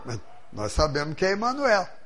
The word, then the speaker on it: Emanuel: Ele disse, eu nunca falei Emanuel, porque é um nome hebraico. Esses nomes hebraicos, todos que terminam em El, têm o acento na última sílaba.